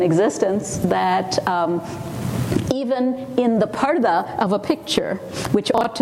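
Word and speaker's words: parda (پردہ): It's like the word people use in English, P-U-R-D-A-H, which they pronounce purda sometimes, but it's parda, it means being in a curtain or being behind a curtain.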